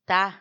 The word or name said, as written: ta